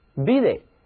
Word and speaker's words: busy: 字母 U 读作短 E， busy，只要把这个 Y 变成 I，然后呢再加上一个副词后缀 L-Y，就成了副词 busily，忙碌的，繁忙的。